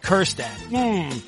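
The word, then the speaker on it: Kjerstad: But there's a silent J in Kerstad.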